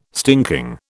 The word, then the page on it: stinking: British English